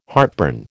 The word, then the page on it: heartburn: American English